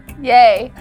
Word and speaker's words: Ye: Is that what people call him? – Yay.